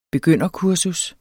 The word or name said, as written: begynderkursus